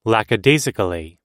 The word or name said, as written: lackadaisically